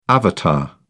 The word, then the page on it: avatar: British English